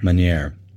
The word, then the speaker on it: Meunier: Oh, Pinot Meunier. – Meunier, yes.